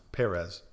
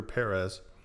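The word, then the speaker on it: Pérez: P2 for Perez, or Perez, as some listeners have reminded me that it's more accurately pronounced Perez. So P2 for Perez.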